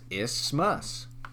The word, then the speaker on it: isthmus: The correct pronunciation is isthmus.